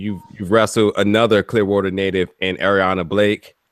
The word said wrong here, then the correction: Aria Blake: Blake. Aria Blake. Yes, I keep saying say it like the hotel, and I just keep forgetting.